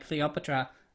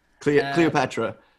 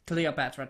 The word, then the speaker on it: Cleopatra: I mean, yeah, if you were Cleopatra, oh, butchered that name. – Cleopatra. – Cleopatra, thank you.